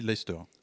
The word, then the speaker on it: Leicester: Donc musique post-punk, ça s'écrit Leicester et ça se dit Leicester.